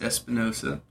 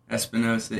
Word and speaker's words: Espinosa: So, my name is Luis Espinosa, but I prefer to spell it Lui, L U I, drop the S, mm-hmm. and then uh, Espinosa, E S P I N O S A.